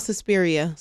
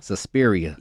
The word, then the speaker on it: Suspiria: And you spoke on, I don't want to mispronounce the movie that influenced. – Oh, it's called Suspiria. – Suspiria.